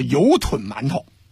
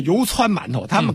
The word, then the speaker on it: 油汆馒头: 再有一个呢，除了这个生煎包以外，南方有一个，这个读音应该读成叫做油吞馒头，或者叫油汆馒头，他们可能是不是读，我不知道上海人怎么读这音。